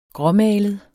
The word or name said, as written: gråmalet